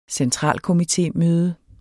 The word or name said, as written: centralkomitémøde